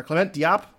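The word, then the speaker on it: Clément Diop: Clement Diop, am I pronouncing it correctly? – That sounds right to me. That's what I would have said.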